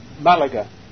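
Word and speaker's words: Málaga: He studied all classic languages, and eventually, because he had to run away from Cordova, settled in Malaga. Or Malaga. I'm not sure how to pronounce it. How do you pronounce it? – Malaga.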